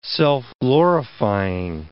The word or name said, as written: self-glorifying